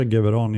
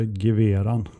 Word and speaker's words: GVRAN: Du sa Geveran, jag sa Geveran. – Ja, jag ska inte ta gift på att det är exakt så man uttalar det, men jag tycker att det lät bra när jag, när, när jag sa det, så jag står fast vid det.